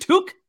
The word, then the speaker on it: toque: And we always have the conversation if it's called a beanie or a toque. – Oh, yeah. Okay. So what is it? – It's a beanie in my neck of the woods, but we'll go with toque. What is it? Toque? – Toque. T-O-Q-U-E.